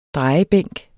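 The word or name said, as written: DREJEBÆNK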